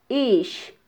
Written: ix

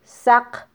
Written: saq

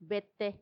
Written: bette

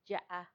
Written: yaa

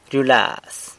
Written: ryulaz

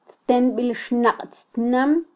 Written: ttenbilxnaqtztnam

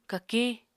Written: kakiñ

